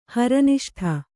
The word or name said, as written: ಹರನಿಷ್ಠ